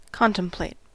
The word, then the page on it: contemplate: American English